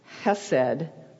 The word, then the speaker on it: hesed: The root of this word is pronounced hesed with a little kind of a roll in the back of your throat. Hesed. I can't do it.